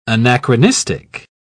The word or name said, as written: anachronistic